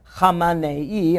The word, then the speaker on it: Khamenei: And then there was a new Ayatollah, Khamenei.